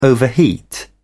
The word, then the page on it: overheat: British English